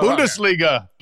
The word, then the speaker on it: Bundesliga: I learned how to say, but I've since forgotten, Bungledisha. Is that how you say it? – Bungledisha. – Bundesliga. Right? – Bundesliga.